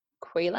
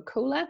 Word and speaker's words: Kuler: And a good way to do, like, to come up with a secondary color palette, there's a tool called Qler, I think it's called, or Qler, but it's spelled K-U-L-E-R.